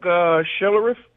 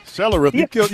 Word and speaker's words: Schlereth: I was thinking Mark Schlereth. – Well, you got it, Big V. You got it. Yes, Mark Schlereth. – Schlereth. You butchered his last name, but we'll take that.